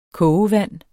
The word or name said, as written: kogevand